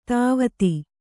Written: ಧಾವತಿ

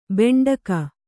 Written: ಭಾಂಡಿಕ

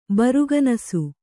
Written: ಬರುಗನಸು

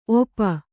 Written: ಒಪ್ಪ

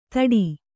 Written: ಥಡಿ

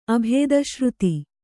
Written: ಅಭೇದಶ್ರುತಿ

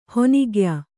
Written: ಹಣ್ಣುಗಾಯಿ

